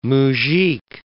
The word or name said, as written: muzhik